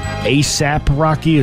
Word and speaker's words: ASAP Rocky: Rihanna and her partner, ASAP Rocky, is that how you say it? – Yes.